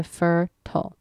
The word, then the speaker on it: fertile: 还有在 fertile 那边它是两个 syllable，还有最后那个 syllable TIL 那边，你就发它的音好像 turtle 的 TLE 尾巴那边就可以了，加起来就是 fertile。